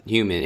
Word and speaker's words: HVMN: I looked at the website, Human. It's spelled HVMN, right? – Yes, it's edgy and Silicon Valley and cool.